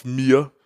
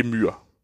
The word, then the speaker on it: Myr: Det, men, og ja, Bare lige hint, det hedder Thoros of Myr, ikke Myr.